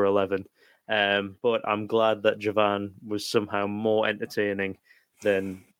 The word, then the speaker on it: Jawan: September saw the release of Jawan. – I believe it's pronounced with a W, which means soldier in Hindi.